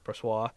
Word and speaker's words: Brossoit: Brossoit.